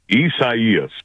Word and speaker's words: Isaias: Isaias.